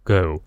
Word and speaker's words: go: 好，第二个呢，就是 go 走，不要念成 go，中间是双母音 go。